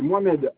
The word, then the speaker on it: Mohamed: Y-A-G-O-U-B. – O-U-B. Un peu votre prénom? – C'est Mohamed, M-O-H-A-M-E-D.